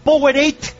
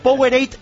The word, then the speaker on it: Powerade: Este estadio, por aquello de los naming rights, que se llama, que se dice así, se llama Powerade, marco mucho la T, para que no me frían en el Twitter, Powerade Stadium, pero vamos, Cornellà Prat de toda la vida.